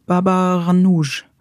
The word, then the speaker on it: Baba Ghanoush: Baba Ghanoush.